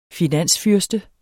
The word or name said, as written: finansfyrste